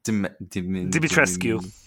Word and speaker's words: Dimitrescu: She looked like how do you pronounce Dimitrescu? – Dimitrescu, yeah, that name.